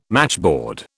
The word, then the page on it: matchboard: British English